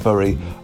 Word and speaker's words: Bury: However, upon further research, it does appear that both Berry and Bury are seen as the correct way of pronouncing it, depending on which side of the town you are from. Berry is usually from the Mancunian side and Bury from the Lancastrian side.